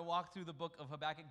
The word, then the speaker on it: Habakkuk: Habakkuk. The book of Habakkuk.